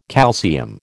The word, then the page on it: calcium: American English